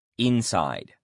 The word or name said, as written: inside